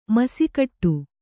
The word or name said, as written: ಮೀಸೆಗಡಿ